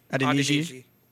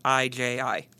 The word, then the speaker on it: Adeniji: Correct my pronunciation if I'm ever wrong on these, but Hakeem Adeniji. – Adeniji. Yep. I'll go first. – Okay. – Hakeem Adeniji. – Correct.